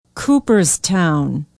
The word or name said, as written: Cooperstown